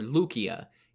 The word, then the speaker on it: Lycia: Caria can be further divided into the northwestern section, which I just described, and the territory of Lycia or Lucia in its ancient Greek pronunciation.